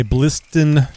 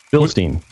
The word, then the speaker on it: Bilstein: Well, let me tell you about my Bliston shocks. Am I saying that right? Is it uh, Bilstein, Bliston? Bilstein. Bilstein.